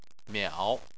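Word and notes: miao3: tone 3

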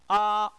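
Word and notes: a: tone 1

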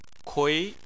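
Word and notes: kui: tone 2

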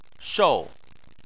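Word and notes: shou: tone 4

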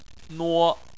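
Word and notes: nuo: tone 1

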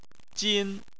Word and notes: jin: 1

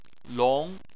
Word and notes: long: 2

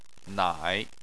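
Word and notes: nai3: tone 3

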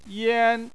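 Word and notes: yan: tone 1

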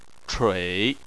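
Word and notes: chui: tone 3

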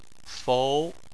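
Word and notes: fou: tone 2

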